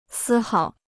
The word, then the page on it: 丝毫: sī háo